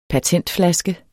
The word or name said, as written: patentflaske